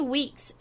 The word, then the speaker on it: Weekes: Yeah, so Remy Weeks is actually, I believe that's how you pronounce Remy's last name. It's spelled W-E-E-K-E-S.